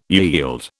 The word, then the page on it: yield: British English